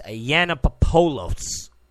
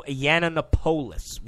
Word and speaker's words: Yiannopoulos: Now, I always pronounce his last name messed up. It's Yiannopoulos. Milo Yiannopoulos. Y-I-A-N-N-O-P-O-U-L-O-S. I always say it wrong.